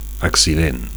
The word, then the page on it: accident: Catalan